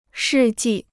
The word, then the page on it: 世纪: shì jì